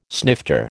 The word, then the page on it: snifter: American English